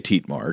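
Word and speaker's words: Thietmar: It is spelled T-H-I-E-T-M-E-R, but I think it would be Tietmar, sort of Dietmar.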